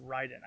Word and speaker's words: Raiden: Rai, which means thunder, and Den, which means lightning in Japanese. So it's actually pronounced Raiden.